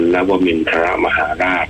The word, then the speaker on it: นวมินทรมหาราช: นวมินทรมหาราช